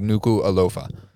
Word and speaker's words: Nuku'alofa: They pronounce everything phonetically. So, like, as it's, like, exactly as it's spelled is normally how it's pronounced. So, it's literally Nuku. They pronounce every word. So, it's like Nuku'alofa.